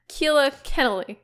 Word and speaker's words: Keala Kennelly: No, Keala Ken- Keala Kennelly. Sorry. So Keala Ken- Kennelly.